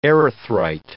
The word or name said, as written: erythrite